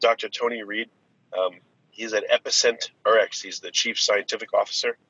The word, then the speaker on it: EpicentRx: So just to help you out, the company is EpicentRx.